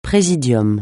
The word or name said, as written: praesidium